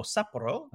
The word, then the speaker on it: Sapporo: Heading to Sapporo in Hokkaido, or Sapporo, yep, as it's pronounced, judging at the International Beer Cup in Sapporo.